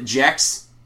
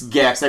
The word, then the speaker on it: Gex: Well, how do you know it's pronounced Gex? Isn't it Jex? No, it's Gex. I programmed the fucking thing.